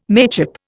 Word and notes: mateship: Indian

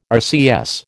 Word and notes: RCS: American English

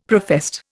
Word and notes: professed: Indian

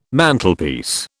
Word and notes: Mantelpiece: British English

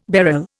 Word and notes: beryl: Indian

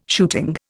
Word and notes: shooting: Indian

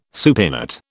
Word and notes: supinate: British English

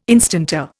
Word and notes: instanter: Indian

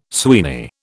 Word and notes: Sweeney: British English

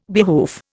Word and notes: behoof: Indian

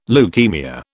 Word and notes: leukaemia: British English

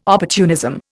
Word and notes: opportunism: Indian

